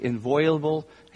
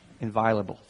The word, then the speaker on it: inviolable: That same voice appealed to the scriptures again and again and again throughout his life on earth as the absolutely inviolable. I said that wrong. Can someone say inviolable for me? Can you do it? Inviolable. Thank you.